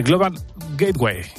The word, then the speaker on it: Global Gateway: La nueva ruta de la seda, el dinero del Global, a ver si lo digo bien, del Global Gateway.